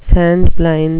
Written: sand-blind

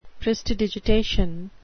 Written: prestidigitation

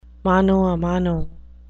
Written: mano a mano

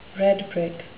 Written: redbrick

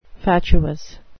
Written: fatuous

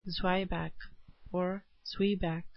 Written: zwieback

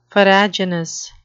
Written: farraginous

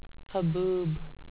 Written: haboob

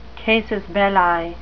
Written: casus belli

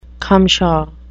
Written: cumshaw

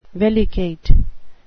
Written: vellicate

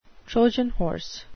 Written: trojan horse

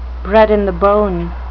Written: bred-in-the-bone

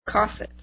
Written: cosset

